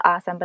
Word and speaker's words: AUSM: And so the nonprofit that you work for is called Awesome, but it's not spelled like the word awesome. It's AUSM.